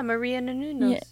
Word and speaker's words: Menounos: Yeah, Maria Menounos. Yeah. Menounos. – Yeah. – Maria Menounos. – Menounos. – No, she said it right in the first time, but you guys have said it so many times that I know I can't even think of what it actually is.